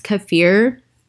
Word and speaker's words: kefir: Another one that they suggest also that Sam and I have not done in a while, it's pronounced kefir.